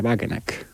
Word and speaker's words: Wagenknecht: es el de Sarah Wagenknecht, o como claro.